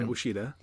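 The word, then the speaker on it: Ouachita: And like that's such a big leap from Uesheita Baptist, which I'm still not sure I'm pronouncing right. – Pretty sure that's right, Uesheita.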